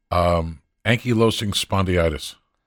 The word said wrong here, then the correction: ankylosing spondylitis: What is it? – Ankylosing spondylitis. – Spondylitis. Thank you.